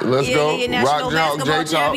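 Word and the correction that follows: Jayhawk: You know, how you say it? Rock Chalk, J-Hawk.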